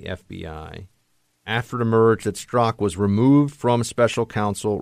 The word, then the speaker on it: Strzok: And I think it's going to get a lot worse for them on this issue of Peter Strzok, as I found out that's how we say his name.